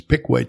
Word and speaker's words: Piqua: And not too long ago, I went out to Piquay or Piqua, Kansas, where he was born. It should be pronounced Piqua. But it's Piquay to them.